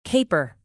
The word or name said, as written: caper